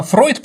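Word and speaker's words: Freud: Соответственно, Фройд, потому что немецкий дифтонг «еу» считается как «ой». Соответственно, Фройд. Ну, дифтонги в немецком языке – это известные вещи, допустим, «ие» и «еи», да, можно запомнить как «зигхайль», да, там четко «сиек», «хайль», ну вот, соответственно, поэтому Фройд в основном, но у нас перевели как Фрейд,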